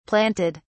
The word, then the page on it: planted: British English